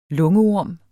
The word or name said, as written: lungeorm